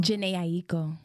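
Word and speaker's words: Jhené Aiko: Janae Aiko? – Janae Aiko.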